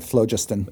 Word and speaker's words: phlogiston: And those kind of whirl around in the phlogiston, how do you want to pronounce it? Help me out here. – You could call it phlogiston. – Okay, phlogiston. We'll go with phlogiston.